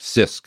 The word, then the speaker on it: CISC: CISC.